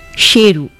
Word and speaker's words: Sheru: Little Saru had been mispronouncing his own name for years. His actual name was Sheru, which translates to lion.